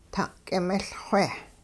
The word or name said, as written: ta'k'imiLxwe